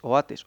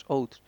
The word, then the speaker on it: Oates: El siguiente fue el capitán Oates, creo que se pronuncia así. Es Oates, Oates.